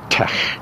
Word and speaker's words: TeX: It's not the approved pronunciation because the letters are actually the anglicized versions of the Greek letters tau, epsilon, chi. And so the T-E-X is allegedly pronounced tech.